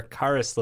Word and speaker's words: Caris: There's no way Chris LeVert would, or Caris LeVert. I can't say his name. – Did I say Chris LeVert? – It's Caris.